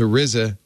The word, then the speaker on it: RZA: Upon learning it was Shkreli who bought the album, Wu-Tang member, how do you say that, Father Robert? Arza? – The RZA told Bloomberg the group decided to give a significant portion of the proceeds to charity.